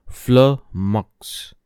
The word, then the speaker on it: flummox: फ्लमक्स जी हाँ दोस्तों which is pronounced as फ्लमक्स